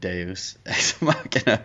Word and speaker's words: deus ex machina: I will agree that I feel Negate is a bit of a deus ex machina. – Which I was okay. – A literal walking one. – And I just realized I think I called it deuce instead of deus ex machina.